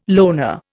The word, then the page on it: loner: Indian